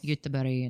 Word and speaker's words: Göteborg: He's got his Google Map thing of the city we're moving to, which is Göteborg. – What's called? – Yeah, Gothenburg if you're American. Anyway, what? Why is it Gothenburg if you're American? – Because that's it in English. It's Göteborg in Swedish.